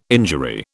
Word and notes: injury: British English

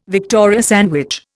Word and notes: Victoria sandwich: Indian

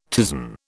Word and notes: ptisan: British English

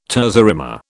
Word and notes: terza rima: British English

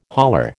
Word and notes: hauler: American English

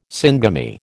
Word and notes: syngamy: American English

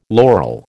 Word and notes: laurel: American English

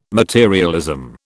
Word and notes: materialism: British English

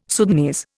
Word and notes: Sudanese: Indian

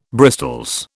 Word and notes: bristols: British English

